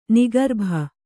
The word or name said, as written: ನಿಗರ್ಭ